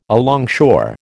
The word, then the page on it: alongshore: American English